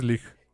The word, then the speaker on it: Leigh: Estoy seguro que es Leigh, porque es L-E-I-G-H. – Creo que es Leigh. – Aquí estoy casi seguro que es Leigh, que así se pronuncia.